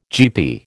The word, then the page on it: GP: American English